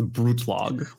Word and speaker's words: Brutlag: It's German. So if you just think of like attacking it strongly, it's a Brutlag.